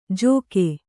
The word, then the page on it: ಜೋಕೆ: jōke